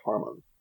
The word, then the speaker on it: Hamon: H-A-M-O-N is the word. Hamon, but they always pronounce it with an R. Harmon.